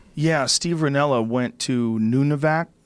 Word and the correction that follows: Nunavut: Is that it? – Nunavut. Yeah, something like that.